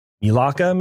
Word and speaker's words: Melaka: Disney's planning on building the resort in Melaka. That's a bad word in Greek, so don't call anybody that. But it's M-E-L-A-K-A. Melaka, maybe? Okay. It's Melaka.